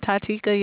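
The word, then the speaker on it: Titeaka: I'm Tatika.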